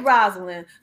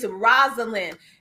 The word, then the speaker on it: Rosalind: All right, Rosalind. So we're going to make sure you all that's listening in, it's Rosalind.